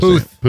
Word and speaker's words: Puth: And in music news, Charlie, I could never pronounce his last Puth. – Name. Puth. – Puth.